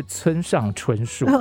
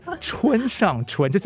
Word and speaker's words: 春上春: 这个发音要咬字要清楚，不是村上春树，是是春上春，就是比你这个春梦更加的春，对。